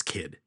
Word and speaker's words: Kyd: of Thomas Kidd today. And that's spelled K-Y-D.